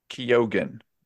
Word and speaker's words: Keoghan: Barry Keoghan, apparently, is how you say his last name.